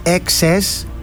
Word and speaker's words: INXS: Κάποια φορά μη είχε ρωτήσει ένας, θυμάμαι, πρόπερση, πάλι που είχα βάλει ένα τραγούδι των INX, γιατί τους λέω INX, έτσι για γρηγοράδα εγώ, προφέρεται INXS, έτσι είναι το κανονικό τους,